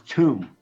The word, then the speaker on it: Tuam: It's called Choom. – Oh, Choom. Choom. – Choom. – Choom. Choom. – Yeah. – So the A is silent. – Look at any kind of Gaelic spelling, it's all messed up. – It's Tuam, but it's pronounced with a CH, Choom. – Oh, Choom. Okay. – Like Choom is how it's pronounced.